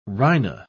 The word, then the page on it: rhino-: British English